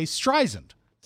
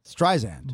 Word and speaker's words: Streisand: It's a soft S. I never knew that. I would say Streisand. – Streisand. – But it's Streisand, and apparently that's sort of a sticking point for her.